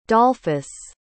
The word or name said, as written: dolphus